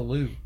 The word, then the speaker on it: Louvre: It's L-O-V-R-E. – L-O-U-V-R-E. – Yeah, what is that? – Louvre. – Did I say that right? – Yeah.